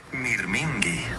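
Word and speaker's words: mirmingi (μυρμήγκι): Se dice mirmingi. Suena como mirmingi, más o menos.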